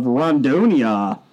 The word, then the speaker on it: Rondônia: The 31-year-old model and dancer, mostly dancer, was representing the northern Brazilian, yes, she was, state of Rondônia.